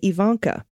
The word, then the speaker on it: Ivanka: It starts with a long E sound, not a long I sound. Okay, Ivanka.